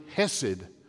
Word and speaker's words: hesed: The Old Testament word is C-H-E-S-E-D, or you make the C a silent letter, so it's H-E-S-E-D, hesed.